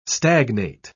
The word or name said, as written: stagnate